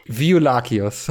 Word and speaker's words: Violaceus: Und das ist, äh, wie sich auch im Laufe der Zeit äh, herausstellt oder auch recht bald, Vio Violaceus, habe ich das richtig gesagt? – Violakeus. – Violakeus, okay, sagen wir Violakeus, das hört sich eigentlich ziemlich gut an.